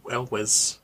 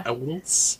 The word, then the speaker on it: Elwes: Oh, Ca- Cary El- oh, I don't know how to say it, but um, Cary El- Elwes. – Yeah, Elwes.